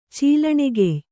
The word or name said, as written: ಚೀಲಣೆಗೆಯ್